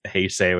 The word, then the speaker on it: Heisei: I've gone with Heisei, which I think is the closest to the Hepburn pronunciation.